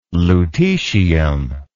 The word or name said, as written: lutecium